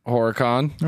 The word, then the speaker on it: Horicon: Horicon? Horicon? – Horicon? – H-O-R. – Yes. – I-C-O-N. How would you pronounce that? – Horicon.